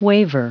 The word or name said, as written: waver